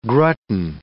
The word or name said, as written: grutten